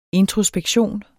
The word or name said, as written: introspektion